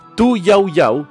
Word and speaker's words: Tu Youyou: Tu Yao Yao se escribe T-U-Y-O-U-Y-O-U,